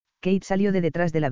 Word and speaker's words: Caitriona: Suena como Kate pero se escribe distinto».